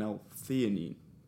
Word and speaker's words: L-theanine: And then I'll take an L-theanine tablet.